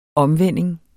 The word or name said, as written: omvending